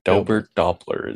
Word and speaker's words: Delbert Doppler: Delbert Doppler is his name.